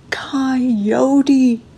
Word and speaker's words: Coyote: Thus the helpless relent and call out to God, who proceeds to pronounce the name Coyote with all three syllables.